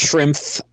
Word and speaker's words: Schrempf: And it is pronounced Schrempf at the end.